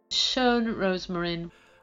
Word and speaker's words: Schön Rosmarin: Schön Rosmarin. – Let's have a go. Schön Rosmarin. – Schön Rosmarin.